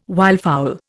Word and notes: wildfowl: Indian

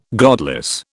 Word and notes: godless: British English

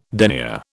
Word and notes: denier: British English

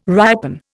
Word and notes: ripen: Indian

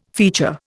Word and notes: feature: Indian

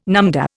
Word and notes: numdah: Indian

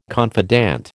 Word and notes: confidant: American English

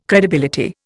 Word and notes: credibility: Indian

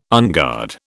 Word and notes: unguard: British English

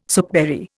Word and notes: soapberry: Indian

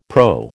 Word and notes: PRO: American English

